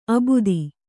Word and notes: ಅಬುದಿ: Kannada

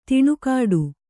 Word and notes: ದನಿಗೊಡು: danigoḍu

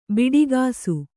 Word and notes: ಬಿಡಿಕಾಸು: biḍi kāsu

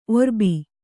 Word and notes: ಒರ್ಬ: orba

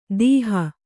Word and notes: ದೀಹ: kannada

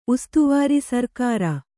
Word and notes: ಉಸ್ತವಾರಿ: ustavāri

